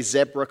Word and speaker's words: zebra: zebra crossing. That's right, I said zebra crossing because that's how it's pronounced, Z with a Z at the beginning.